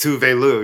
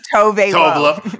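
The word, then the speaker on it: Tove Lo: It's Tuve Lu, yes. – Thank you. I've been saying Tove Lu.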